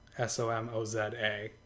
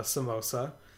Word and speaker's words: Somoza: Somoza. It's not a samosa. I had a lot of trouble with this. I have to very carefully pronounce it.